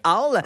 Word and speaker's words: owl: Owl, je me souviens jamais comment on le prononce O-W-L? En anglais. – Ouais. Owl.